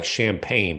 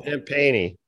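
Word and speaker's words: Champagnie: Looks like champagne, but that ain't how you say it. – I think it's Justin. – No, Champagne-y.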